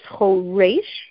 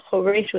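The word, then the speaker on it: choresh (חורש): In other words, it says choresh with a tsere. The fact that it's choresh with a tsere, the fact that there's an accent on the final syllable, means he sharpens and shines all the tools of craft.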